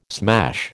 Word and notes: smash: American English

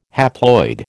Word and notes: haploid: American English

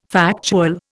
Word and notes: factual: Indian

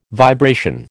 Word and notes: vibration: American English

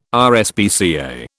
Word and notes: RSPCA: British English

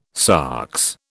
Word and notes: sox: British English

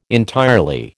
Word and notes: entirely: American English

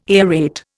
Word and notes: aerate: Indian

